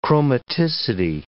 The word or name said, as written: chromaticity